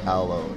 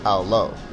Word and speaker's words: aloe: But I think it's just pronounced aloe. O, the long go. O-E, when two vowels go walking, the first one does the talking. – Aloe, and it says its own name.